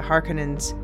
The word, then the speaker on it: Harkonnens: the Harkonnens, and yes, I'm using the more Finnish pronunciation now, thank you Marilyn, and the Atreides.